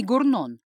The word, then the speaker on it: Горнон: Юношу, который пытался унести из дома проклятого чиновника фальшивое зеркало, звали Рами Горнон. Он даже уточнил, что в фамилии ударение на второй слог.